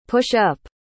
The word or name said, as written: push-up